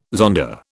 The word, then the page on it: zonda: British English